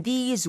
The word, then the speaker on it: these: 하나는 this, 단수는 this, T-H-I-S, 짧게 this. – This. – 근데 여러 개면 복수일 때는 좀 길게 하셔야 돼요. – These.